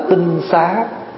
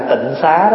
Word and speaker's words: tinh xá: Bây giờ quý vị hay đọc trại là tịnh xá đó. Chữ tịnh xá đó là mình đọc trại. Nếu mình đọc cho đúng là phải đọc là tinh xá.